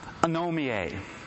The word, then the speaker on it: anomie: It's pronounced anomie.